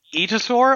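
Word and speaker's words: aetosaur: Yeah, it's an etosaur, I believe is how it's pronounced. I was corrected on that by Emily Keeble when I had an interview with her over with TRX Dinosaurs, and she works on them. I kept misreading it and calling them atiosaurs, but I'm pretty sure that it's just etosaurs.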